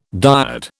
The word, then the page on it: dyad: British English